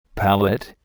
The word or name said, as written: palette